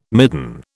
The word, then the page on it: midden: British English